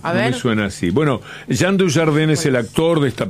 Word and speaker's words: Jean Dujardin: Jean Dujardin. Si al pronunciarlo bastante francesamente no se entiende, se escribe Jean Dujardin.